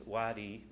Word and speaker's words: WADI: We've chosen to pronounce our acronym since Wadi means a cool protected path through the desert in Arabic and Swahili.